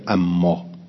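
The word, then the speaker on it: أمّا: خب ان الله لا یستحی ان یضرب مثلا ما بعوضه فما فوقها فاما الذين امنوا فاما الذين لغه العرب جميعا بالتشدید. عربا همیشه میگوین اما،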